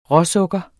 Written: råsukker

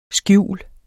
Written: skjul

